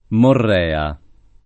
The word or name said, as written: Morrea